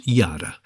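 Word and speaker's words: yada (ידע): The Hebrew word for know in the passage above is pronounced yada, as in yada, yada, yada, which literally means to ascertain by seeing, that is, to perceive.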